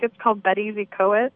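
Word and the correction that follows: Betws-y-Coed: Betws y Coed.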